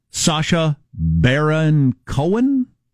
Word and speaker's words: Sacha Baron Cohen: We also got Sacha Baron Cohen.